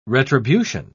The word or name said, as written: retribution